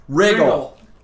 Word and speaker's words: Riggle: Riggle. Rhymes with wiggle.